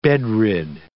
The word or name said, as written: bedrid